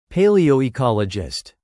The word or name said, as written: paleoecologist